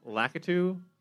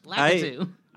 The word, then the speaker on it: Lakitu: Oh, Lakitu. – Word. – Yeah. you pronounced it the way that like Bugs Bunny or Daffy Duck would. Lakitu.